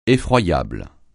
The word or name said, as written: effroyable